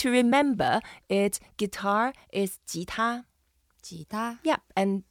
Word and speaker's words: 吉他: 吉 is the second tone. You go up a little bit. 吉. And 他, it's the first tone, so we keep it flat. 他. – 吉他, beautiful.